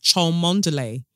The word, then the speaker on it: Cholmondeley: Now, the thing is, Cholmondeley really gets me because it's spelled Cholmondeley. So I, using all of my eyes and how I've been told to pronounce words, would call that Cholmondeley. But apparently it's Cholmondeley.